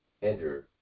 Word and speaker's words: Endor: Let's make sure that I'm pronouncing this word right, this name. A person with a familiar spirit, Endora, I believe it's pronounced. – Ender. – Well, Ender.